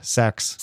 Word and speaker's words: seax: that's how you pronounce that word that is spelled S E A X. – Yeah, sax. Sax, like yeah. sax, like a saxophone.